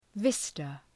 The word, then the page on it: vista: ‘vıstə